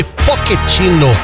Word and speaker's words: Pochettino: lembrando que o nome do técnico da seleção dos Estados Unidos se pronuncia, o último nome, Pochettino, Maurício Pochettino.